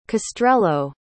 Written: castrello

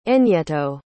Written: enyeto

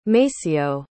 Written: maceo

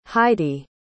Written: heidi

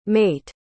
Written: mayte

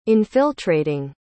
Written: infiltrating

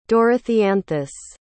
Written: dorotheanthus

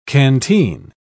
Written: canteen

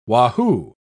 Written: wahoo

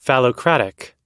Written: phallocratic

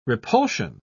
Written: repulsion

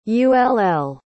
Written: ull

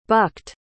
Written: bucked

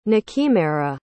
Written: nakimera

